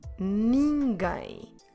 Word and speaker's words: ninguém: Como se escreve ninguém? N-I-N-G-U-E com acento M. Ninguém. Again, we have a silent U.